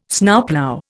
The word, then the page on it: Snowplough: Indian